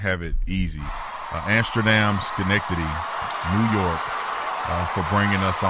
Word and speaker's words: Schenectady: So I want to give a special shout out to Amsterdam Schenectady. Am I saying it right? – Schenectady. – I knew I had it right. Amsterdam Schenectady.